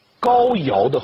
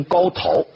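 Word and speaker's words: 皋陶: 这些小国都是高尧的后裔，上古有个帝王叫高尧啊，这个字很多人念成高陶是错的，陶渊明的陶啊，但是在这里念尧这个字，是高尧氏的后裔，都是子爵的小诸侯国。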